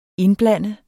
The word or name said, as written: INDBLANDEDES